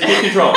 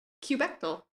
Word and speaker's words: kubectl: No, because you pronounced it kube control. Excuse me? In this house? – It is kube control. That is what the documentation specifies. – I prefer the alternate pronunciation kubectl.